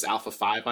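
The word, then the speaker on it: ALPHV: They're also kind of known as Alpha 5, I call them. You know, it reminds me of sort of the Power Rangers. It's spelled ALF and then V. So pronounce it how you want.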